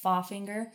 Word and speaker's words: Pfaffinger: Klaus Pfaffinger, sorry, it's spelled like P-F-A-F-F, so it's like Pfaffinger.